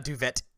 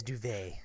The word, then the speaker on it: duvet: And the T is silent at the end of duvet. – Yes, it is not duvet, it is duvet.